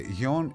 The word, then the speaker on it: Jon: Θα ήθελα όμως, αν μπορείτε με ελληνικούς χαρακτήρες, το J-O-N που μου στέλνετε για το Lord, πώς προφέρεται, Γιον ή Ζων.